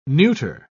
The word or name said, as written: neuter